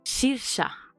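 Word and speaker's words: Saoirse: ¿Sabes cómo se pronuncia Saroís? Saoris, o como se dice, como se escriba. Su nombre se dice Sirsa. – ¿En qué idioma? – En el suyo, en irlandés, que vamos, en inglés irlandés, pero vamos, en inglés se dice Sirsa.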